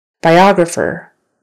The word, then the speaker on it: biographer: Biographer. Biographer. Now the accent is on the second syllable.